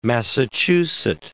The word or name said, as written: Massachusett